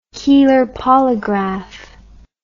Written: keeler polygraph

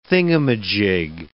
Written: thingumajig

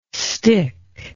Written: stich

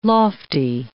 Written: lofty

